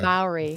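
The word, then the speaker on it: Māori: And if you don't know what we're talking about, this is, I want to pronounce it correctly, the Mora tribe? – Maori tribe. Maori.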